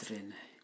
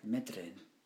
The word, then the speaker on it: Meddryn: And it's spelled? – Medrin. M-E-D-D-R-Y-N. Medrin. – The Medrin bit was all right. The spelling out was creepy. – M-E-D-D-R-Y-N. Medrin. Wow, it's intense.